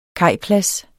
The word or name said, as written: kajplads